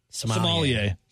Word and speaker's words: sommelier: A sommelier? Say it for me. – Sommelier. – Thank you. I got to say it wrong twice before I get it right the third.